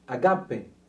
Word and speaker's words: agape: Agape. Yeah? Am I pronouncing it rightly? – Mm-hmm.